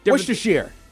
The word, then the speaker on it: Worcestershire: It is a certain kind of sauce that people like to put on different Worcestershire. – Correct. Worcestershire sauce, and you absolutely said it correctly.